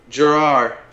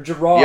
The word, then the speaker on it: Girrior: How do you pronounce your last name? – Gerard. – Gerard? Or Gerard? – Yep, Gerard.